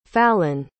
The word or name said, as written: fallon